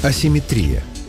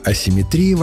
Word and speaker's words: асимметрия: Вариант асимметрия словарями не поддерживается и считается ошибочным. Так что в русском языке симметрия есть, а вот асимметрии нет.